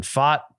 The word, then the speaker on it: Pfaadt: We know now to pronounce it Fott. P F A A D T.